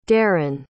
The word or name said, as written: deron